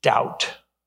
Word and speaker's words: Doubt: Doubt. Doubt. That five-letter word. D-O-U-B-T. Doubt. The B is silent.